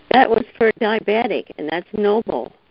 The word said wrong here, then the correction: Nopal: It's Nopal. It's spelled N-O-P-A-L, Nopal.